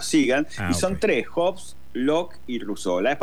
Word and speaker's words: Hobbes: Se escribe H-O-B-E-E-S, Hobbes. – Sí, Hobbes, Thomas Hobbes, hay 8.